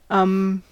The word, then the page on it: um: American English